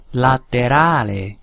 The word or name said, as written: laterale